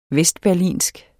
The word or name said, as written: vestberlinsk